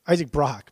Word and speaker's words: Brock: Have I been getting his name wrong? – Isaac Brock. Brooks Brock? – Okay. Oh, I thought, oh, fuck, I've been saying his name wrong the whole time. It's Isaac Brock, yeah. Yeah. – Yeah. Brock, like, okay, sorry. Yeah, or like Brock from Pokemon.